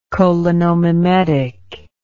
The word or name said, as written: cholinomimetic